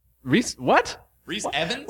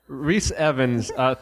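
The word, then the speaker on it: Rhys Ifans: Rhys Evans, what are you doing here? – He's here from his podcast. – He did a great job in the movie. – From his podcast, pronounce my name right, goddammit. – Well, Rhys Ivins, thank you for correcting us.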